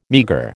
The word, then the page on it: meagre: American English